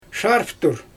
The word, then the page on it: ʃarftur: Xyzyl